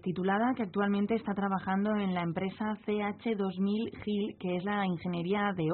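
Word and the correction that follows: CH2M Hill: No sé si lo he dicho bien. ¿Está bien dicho? – CH2M GIL. – M-GIL, bueno.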